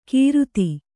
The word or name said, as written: ಕೀರುತಿ